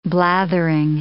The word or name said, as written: blathering